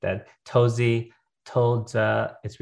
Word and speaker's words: Touzi: How well, I say tozi. So that's how people should probably say it. But in the correct pronunciation in Mandarin, which I don't speak very well, it's toza. And I will never say like that, but it's tozi basically.